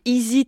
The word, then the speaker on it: Izi: Ça se fait grâce à une application hein, qui s'appelle Easy Travel. Easy s'écrit I-Z-I.